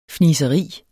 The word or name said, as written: fniseri